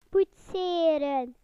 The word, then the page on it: modelling: British English